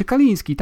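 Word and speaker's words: Kalinske: że pre ten prezes amerykańskiego oddziału SEGI nazywał się Kaliński, tak się go wymawia, to, tak, nie?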